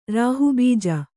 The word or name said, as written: ರಾಹುಬೀಜ